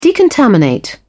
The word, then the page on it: decontaminate: British English